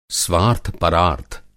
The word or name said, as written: स्वार्थ-परार्थ